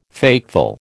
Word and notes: fateful: American English

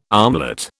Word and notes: armlet: British English

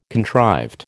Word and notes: contrived: American English

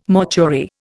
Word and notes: mortuary: Indian

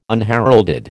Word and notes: unheralded: American English

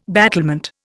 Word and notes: battlement: Indian

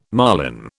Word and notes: marline: British English

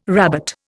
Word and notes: rabbet: Indian

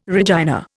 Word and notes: Regina: Indian

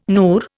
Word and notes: NUR: Indian

